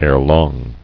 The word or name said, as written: Erelong